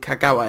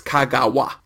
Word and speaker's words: Kagawa: There's no gao sound in it, so it is not Kagawa. And there's no emphasis in Japanese, apparently, or there's no emphasis in Kagawa anyway, so you just hit all the syllables equally. It's Kagawa, Kagawa, Kagawa.